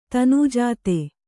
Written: ತನುಜಾತೆ

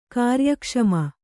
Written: ಕಾರ್ಯಕ್ಷಮ

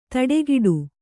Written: ತಡೆಗಿಡು

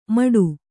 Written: ಮಡು

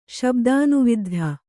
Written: ಶಬ್ದಾನುವಿದ್ಧ

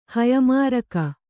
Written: ಹಯಮಾರಕ

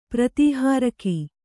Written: ಪ್ರತಿಹಾರಕ

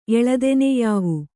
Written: ಎಳದೆನೆಯಾವು

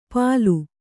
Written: ಪಲು

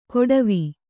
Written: ಕೊಡವಿ

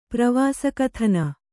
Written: ಪ್ರವಾಸಕಥನ

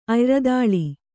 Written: ಅಯ್ರದಾಳಿ

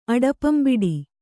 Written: ಅಡಪಂಬಿಡಿ

